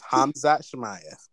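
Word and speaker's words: Khamzat Chimaev: It's Hamza Chimaev.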